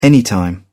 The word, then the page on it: anytime: British English